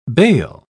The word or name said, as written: bale